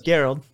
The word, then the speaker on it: Gerald: And so, and forgive me, like Gerald or Gerald? I want to say Gerald. – It is with a hard G.